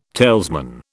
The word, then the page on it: talesman: British English